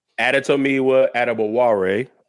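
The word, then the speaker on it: Adetomiwa Adebawore: Adetomiwa Aduba Ware is how you pronounce it.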